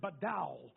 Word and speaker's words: badal: Difference is the Hebrew word badal, is how you pronounce it.